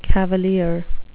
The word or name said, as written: cavalier